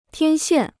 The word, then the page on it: 天线: tiān xiàn